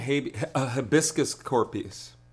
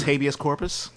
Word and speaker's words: habeas corpus: What, and wasn't hibiscus corpus? – Oh, my God. – She mispronounced habeas corpus? – Yeah.